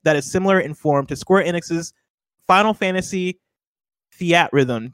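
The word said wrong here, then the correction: Theatrhythm: I think maybe I'd say Theater Rhythm.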